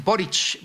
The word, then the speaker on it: Boric: Además, yo creo que lo pronuncia bien don Martín Alonso. Es Boric.